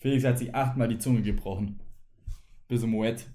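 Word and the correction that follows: Moët: äh, Moet aussprechen konnte.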